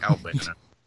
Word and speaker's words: Albano: We keep calling him Cabano, and it's Captain Lou Albino, but yeah, whatever. Nah, I can't pronounce Albino. Albino, Albano. That's delightful.